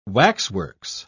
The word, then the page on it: waxworks: British English